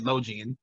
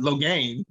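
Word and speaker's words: Logain: Loghain, I always said Logian, but Loghain. – You heard them say it, then you're like, oh, that's how you pronounce it.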